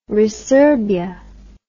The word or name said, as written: resurbia